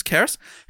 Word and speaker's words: Cerys: It's Welsh, lol, because it's spelled C-E-R-Y-S, but he's written... pronounced K-E-R-S, Keris, who has graduated from medical school and starts life as a doctor soon.